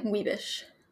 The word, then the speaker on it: Wiebersch: Wiebersch. – Okay, I'd still always say your last name wrong. Wiebersch, even though yes. there's an R. – Yep, R so is silent if it's before an S-C-H in German.